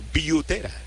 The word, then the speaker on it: Butera: Blake Butera, en la familia de Blake y de Drew, padre e hijo, que fueron catchers en Grandes Ligas, ellos pronuncian el apellido Butera en inglés.